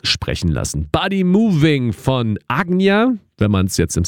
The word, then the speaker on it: Ajna: Ajna wird es eigentlich im Sanskrit ausgesprochen. Die meisten würden wahrscheinlich Ajna zu sagen. A-J-N-A geschrieben, ist aber gleichzeitig auch ein belgischer Produzent. A-J-N-A geschrieben, der sich hier mit Mischa zusammengetan hat.